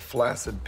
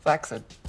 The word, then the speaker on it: flaccid: In fact, what I think I'm seeing is the human equivalent of a flaccid penis. – Flaccid. It's actually pronounced flaccid. Not a lot of people know that.